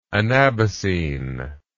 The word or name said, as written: anabasine